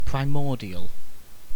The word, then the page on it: primordial: British English